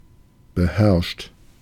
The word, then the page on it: beherrscht: [bəˈhɛɐ̯ʃt]; [bəˈhɛʁʃt]